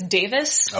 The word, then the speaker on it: Davies: Does he pronounce it Davies or Davis? Because I interviewed John Reese Davis, but it's spelled Davies. So, you know. – From what I understand, it's Davis.